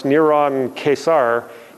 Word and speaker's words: Neron Caesar: In Hebrew values, Nero Caesar's name is actually pronounced Neron Caesar.